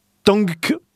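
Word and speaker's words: Tangk: Et le nouvel album, alors c'est pareil, comment on le prononce ça Tank. Donc, Tang comme la boisson, ouais. Mais avec un K derrière. – D'accord. – Ou Tank comme l'engin de guerre, mais avec un G avant le K.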